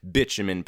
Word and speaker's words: bitumen: I think I called it butamin powder in the last episode. It's actually pronounced bitumen powder.